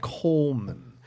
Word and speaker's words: Goldman: Goldman, the G is silent, Coleman.